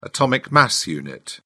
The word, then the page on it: atomic mass unit: British English